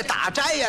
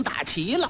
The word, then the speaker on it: 打斋: 小和尚这么一听，哎呀，阿弥陀佛，哈哈，文朝丈庙，哈哈，阿弥陀佛，阿弥陀佛，文朝我也不管，丈庙我也不管，因为我没工夫，我还等着给我师父啊打琪琪呢。他也错了一个，这打斋呀，他给念成打齐了，斋字他念成齐了。